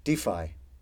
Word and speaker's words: DeFi: Practice that pronunciation, DeFi.